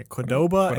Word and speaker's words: Qdoba: Up next is, actually, do you know how to pronounce this one? – Qdoba? – Yeah, Qdoba and Sonic.